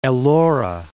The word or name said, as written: Ellora